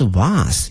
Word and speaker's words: vase: V-A-S-E 哈它有两个发音一个是 vase 一个是 vase 哈都可以